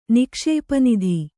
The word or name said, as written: ನಿಕ್ಷೇಪನಿಧಿ